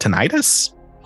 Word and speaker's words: tinnitus: Kind of like, how is this pronounced? Tinnitus? – Yes. – Yeah, that's an tinnitus.